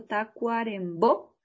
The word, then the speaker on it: Tacuarembó: Estamos hablando del gran Mario Benedetti, quien nació en Paso de los Toros, Tacuarembó. Perdón si de pronto estoy pronunciando mal el nombre, vamos de nuevo, Tacuarembó, el 14 de septiembre de 1920, y que lamentablemente falleciera en Montevideo, Uruguay, el 17 de mayo de 2009, hace poco.